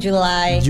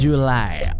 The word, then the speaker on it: July: J-U-N-E ya. Jadi E-nya nggak dibaca, jadi cuman June aja. Terus abis itu, nah ini banyak yang keliru nih. Mungkin Juli dibacanya tetap Juli. Itu sedikit berbeda ya. – Beda. Kalau di bahasa Inggris kan pakai Y, jadi dibacanya July. – July.